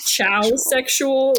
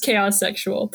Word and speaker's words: chaos sexual: Which it kind of looks like chow sexual when you read it, but it's pronounced chaos sexual.